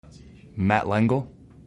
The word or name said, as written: Matt Lengel